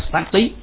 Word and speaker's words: sati: Niệm ở trong Pali đọc là sati, có nghĩa là sự ghi nhớ hoặc là sự theo dõi.